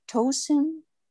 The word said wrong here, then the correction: Toshin: Toshin? – Yeah, it would be Shin.